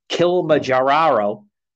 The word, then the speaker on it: Kilimanjaro: And then he also was a hiker that climbed to the top of Mount Kilimanjaro.